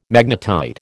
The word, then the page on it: magnetite: American English